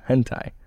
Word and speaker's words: hentai: My best friend mocks me for drawing hentai. Is that how you pronounce it? – Yes. – Okay. It's called hentai, and it's art.